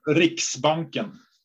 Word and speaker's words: Riksbanken: Riksbanken, you say. – Riks, American accent. Riksbanken, like Handelsbanken. – Think of an X, Riks. – Riks, okay, of course, Riks, Riksbanken.